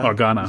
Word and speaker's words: Organa: Morgana, Organa. – Organa. Nicht Morgana.